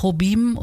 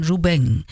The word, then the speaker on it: Jobim: Also nicht Jobim und auch nicht Jobim, sondern Ju Beng. Also Antonio Carlos Jubeng, so wäre die richtige Aussprache.